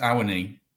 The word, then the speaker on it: Awoniyi: Oh, good question. We've been debating this. We think it's Awoniyi. Yeah.